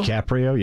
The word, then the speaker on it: DiCaprio: DiCaprio, yep.